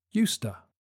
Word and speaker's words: used to: In normal speech, it's pronounced "Usta."